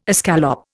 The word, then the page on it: escallop: Indian